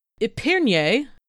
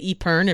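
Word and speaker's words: épergne: Epernier, and I will be saying it like that because that's the appropriate pronunciation, but you can say Epern if you're not sure how to make sounds is a decorative and useful centerpiece made of silver, glass, porcelain, or any other metal.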